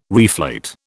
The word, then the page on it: reflate: British English